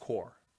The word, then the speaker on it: Corps: It's pronounced Corp. – Really? – Yeah. – Oh, Mr. Businessman. – It's like the Marine Corps. – I thought it was Corp. – No, it's Corp. Yeah. – So the P is always silent. – The P is silent. Yeah.